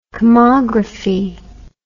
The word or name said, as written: kamagraphy